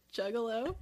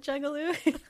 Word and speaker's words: Juggalo: And he was really stoked that he like knew how to pronounce Juggalo, and I said Juggaloo.